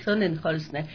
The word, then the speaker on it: Sonnenholzner: Sí, a la final hemos tenido tantos memes que ya realmente, ¿cuál es el apellido? Sonnenholzner. Sí, ¿no? Es Sonnenholzner. Pero no es difícil el apellido porque se escribe... – Se pronuncia como se escribe, exactamente.